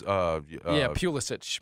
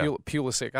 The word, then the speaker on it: Pulisic: But it's Pulis. – Pulisic. Yeah. Pulisic.